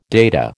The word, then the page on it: data: American English